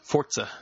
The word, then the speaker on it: Forza: Between Killer Instinct, Forza 5, am I pronouncing that right? Forza? – Forza.